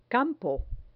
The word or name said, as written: campo